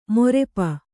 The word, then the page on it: ಮೊರೆಪ: morepa; Kannada